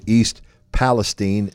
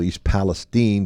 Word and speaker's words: East Palestine: Shadyside looked very strong in going up to East Palestine, is how they say it up there, not East Palestine. – They say Palestine.